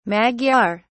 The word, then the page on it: magyar: British English